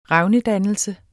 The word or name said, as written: revnedannelse